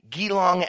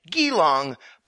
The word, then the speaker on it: Geelong: which actually isn't pronounced with a hard G like Geelong, but a soft G like Geelong, or actually, as Kitty puts it, quote, think Jesus or giraffe, but without the stresses, end quote.